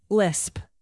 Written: lisp

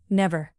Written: never

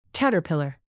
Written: caterpillar

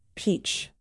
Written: peach

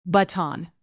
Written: baton

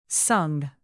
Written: sung

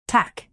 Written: tack

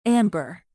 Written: amber

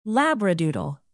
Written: labradoodle

